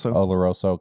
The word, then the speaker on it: Oloroso: And I don't think I was like in tune with everything when the Oloroso, am I saying that right? – Oloroso came out.